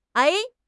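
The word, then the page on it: ai3: tone 3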